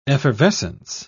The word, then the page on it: effervescency: British English